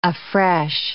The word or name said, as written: afresh